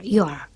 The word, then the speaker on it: your: En inglés es necesario distinguir quién posee la cosa poseída y observar que el adjetivo posesivo yo se pronuncia igual que la forma contraída de you are, your.